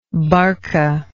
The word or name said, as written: barca